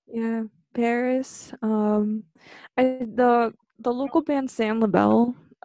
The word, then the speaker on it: PVRIS: Yeah, it's like when I mentioned Paris, which is that band that's spelled P-V-R-I-S. – It's not Privis? – No, it's Paris. See, there you go. It's like churches too. Churches, C-H or C-V-R.